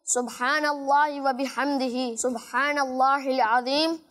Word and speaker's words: سبحان اللہ و بحمدہ: دو الفاظ ہماری اسکرین پر بھی دیکھ رہے ہوں گے, اس کا صحیح تلفظ آپ سن لیجئے, پڑھ لیجئے, سبحان اللہ و بحمدہ,